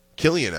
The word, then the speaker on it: Cillian: Okay, how about Cillian Murphy? – Killian, actually. – Oh, yeah. Is it Killian Murphy? – It's Killian Murphy.